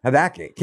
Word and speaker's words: Habakkuk: Habakkuk.